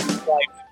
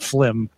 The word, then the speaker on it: Flyme: I have been told it should be pronounced Flyme, not Flim.